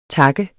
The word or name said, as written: tak